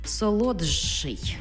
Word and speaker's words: солодший: Тобто пишемо «солодший», а вимовляємо солодший.